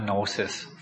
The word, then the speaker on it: gnosis: ถึงแม้ว่าจะมี g เนาะแต่มันกับเราไม่ได้ออกเสียง g แต่ออกเสียงว่า gnosis ทางขวามือนี่นะครับ epignosis ถ้าแปลตรงๆ epi แปลว่าอยู่เหนือกว่าหรืออยู่ข้างบน gnosis คือความรู้ epignosis เป็นความรู้ที่อยู่เหนือแล้วอยู่ข้างบนระหว่าง gnosis